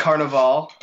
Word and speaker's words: Carnaval: Speaking of parties and laser light shows, you're in a bitchin' band called Carnaval. I found out. It is. I, so I did say it right. You said it right.